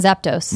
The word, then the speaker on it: Zapdos: Moltres and Zapdos.